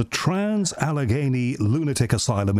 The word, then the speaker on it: Trans-Allegheny Lunatic Asylum: One of them, and I'm sure I'm going to pronounce this wrongly, is the Trans-Allegheny Lunatic Asylum in West Virginia.